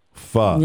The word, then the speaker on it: Pho: Pho. – Pho. – Yep. P-H-O. – P-H-O.